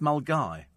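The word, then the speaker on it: Milngavie: I mean, there's an area in Glasgow, Milngavy, and pronounced Mulgai.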